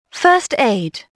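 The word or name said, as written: first aid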